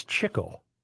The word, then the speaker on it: Chico: And he pronounced Chico. I always called him Chico, the brother Chico. It's Chico.